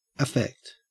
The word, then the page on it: affect: Australian English